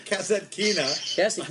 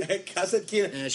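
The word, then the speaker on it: Kasatkina: Kazatkina. – Who? – Kazatkina.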